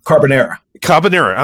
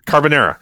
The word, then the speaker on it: carbonara: Carbonara. I'm sorry, I'm mispronouncing it. Carbonara.